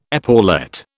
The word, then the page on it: epaulette: British English